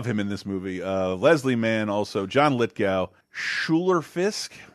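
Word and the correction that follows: Schuyler: Skylar Fisk.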